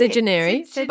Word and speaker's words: Sedunary: That's Sejanary. But I say it's Jess like Sedentary.